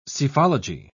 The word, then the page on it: psephology: British English